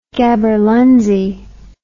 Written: gaberlunzie

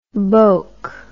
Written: boak

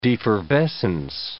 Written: defervescence

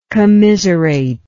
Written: commiserator

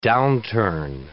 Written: downturn

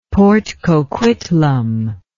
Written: port coquitlam

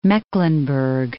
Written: Mecklenburg

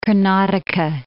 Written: Karnataka

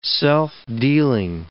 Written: self-dealing

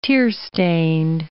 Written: tearstained

